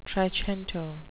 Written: trecento

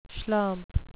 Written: schlump

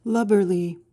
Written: lubberly

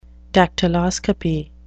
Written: dactyloscopy

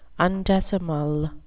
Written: undecimal

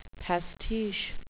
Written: pastiche

